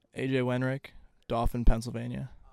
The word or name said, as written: AJ Wenrich